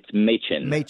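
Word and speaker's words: Mädchen: So Shelley Machen Amick, the great, is it pronounced Machen? How do you pronounce her first name correctly? – It's Machen.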